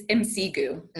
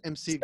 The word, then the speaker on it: Msegu: but is it Misigu? It's Msegu. Msegu. – So like M S E E G O O.